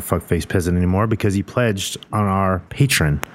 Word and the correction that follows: Patreon: Is that how you say it? Patreon? – Indeed.